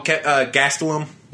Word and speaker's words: Gastelum: Oh, Gastelum.